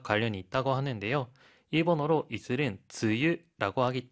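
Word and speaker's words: 즈유라고 (つゆ): 즈유라고 합니다.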